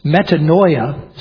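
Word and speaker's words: metanoia (μετάνοια): And actually the word repentance, metanoia, is how it's pronounced in Greek, means the parts of the word, the etymology, the way the word is built, means a change of mind.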